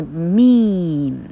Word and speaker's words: meme: 哈、哦，嘿，这个，啊、哦，它实际上就变成了啊，老克最近学的，它就变成了一个 meme，M-E-M-E，哈，M-E-M-E 这样的字，你的念法是 meme，它一定要长音，为什么？因为它的字尾 e 不发音，这时候，呃，这个单节，这个音节里头的。